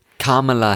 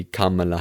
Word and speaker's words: Kamala: Die Vizepräsidentin, die Kamala Harris, wenn ich das jetzt richtig ausspreche, weil sie heißt ja nicht Kamala, sondern Kamala.